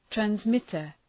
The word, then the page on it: transmitter: trænz’mıtər, træns’mıtər